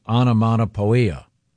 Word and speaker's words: onomatopoeia: But anyway, the description was, it was described as starting with explosive onomatopoeia. – Onomatopoeia. It's spelled poia, but it's pia.